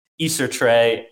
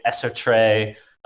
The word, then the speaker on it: Eceertrey: There's no right or wrong way. Like, I like to hear all the pronunciations. So it's like Eceertrey, Eceertrey, Eceertrey, like yes. The hard E.